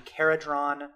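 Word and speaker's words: Kharadron: We've had a lot of releases from a brand new faction called the Caradhron Overlords. – Yeah. Am I saying that right? – Yes.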